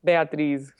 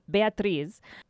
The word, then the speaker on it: Beatriz: How do you say it in Portuguese? – Beatriz. – Yeah, Beatriz.